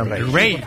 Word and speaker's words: Raye: Ray. Se escribe Raye con Raye.